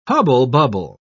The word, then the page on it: hubble-bubble: British English